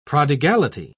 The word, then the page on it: prodigality: British English